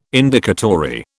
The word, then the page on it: Indicatory: British English